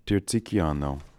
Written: Tirzikiano

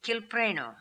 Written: Kilpreno

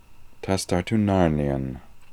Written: tastartoonarnian

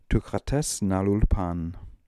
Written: Tookha Tes Nalool Pan

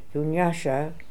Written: Doonyasha

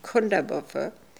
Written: Kundabuffer

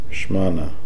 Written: Shmana

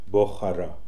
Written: Bokhara

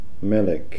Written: Melik

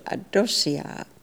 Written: Adossia

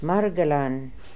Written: Margelan

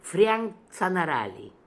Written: Frianktzanarali

